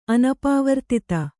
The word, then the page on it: ಅನಪವರ್ತಿತ: kannada